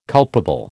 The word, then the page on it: culpable: American English